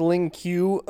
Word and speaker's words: Lin Kuei: I think it's pronounced Lin Q, according to this. – According to this video, it is pronounced Lin Q, despite being spelled L-I-N space K-U-E-I.